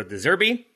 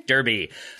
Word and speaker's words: De Zerbi: In Southampton v Brighton, it was Nathan Jones versus Roberto De Zerbi, with Brighton coming out on top. In the wait, who's their manager? Derby. I apologize for mispronouncing Derby for the sake of the rhyme.